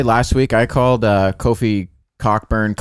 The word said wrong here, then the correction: Cockburn: and it's Coburn. Apparently, that CK is silent.